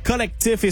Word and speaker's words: CLCTF: Ready for Love, The Collectif, et ça s'écrit C-L-C-T-F.